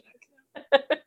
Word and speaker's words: Jacques: And his name is Jacques Lebert, and he's like, oh, it's Jack. Actually, it's pronounced Jack.